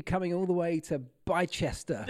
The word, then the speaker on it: Bicester: Bicester? – You got it.